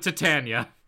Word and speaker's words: Titania: I do, and I prefer the pronunciation with the tit in it. – All right, we'll go with that then. We'll go with Titania.